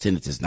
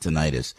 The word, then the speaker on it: tinnitus: It's pronounced tinnitus, not tinnitus.